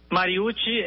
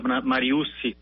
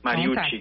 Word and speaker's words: Mariucci: Mariucci é a pronúncia certa, mas eu tenho muitos familiares que têm a escrita com dois S, então acaba que no ambiente familiar a gente também aceita e até usa a expressão Mariucci, a pronúncia Mariucci também, né? Mas é como o meu nome é com dois C no final e I, então a pronúncia correta é Ti. Mariucci.